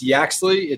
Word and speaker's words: Yaxley: It's Yaxley.